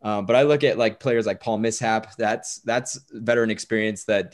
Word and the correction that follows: Millsap: It's Millsap, Paul Millsap.